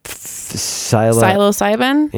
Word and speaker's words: psilocybin: So now, can you say it? How do you say it? – Psilocybin? – Yes. – Is that what psilocybin? Like? Thank you. – Psilocybin. – Psilocybin. Yeah. That's what we're talking about. That is the component. – I used to say psilocybin. I was like, oh, I'm wrong. – I'm so glad someone in this room knew, said it right.